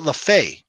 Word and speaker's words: LeFevre: So Jerry and John got traded to the Yankees for Rupert Jones, Joe LeFevre, not Jim, not his brother Jim, Joe LeFevre. – Oh, I believe was that the guy that played for the Phillies? Yeah, he pronounced it LeFay.